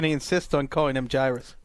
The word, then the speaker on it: Jairus: It's Jarris, and this has come up on the podcast before.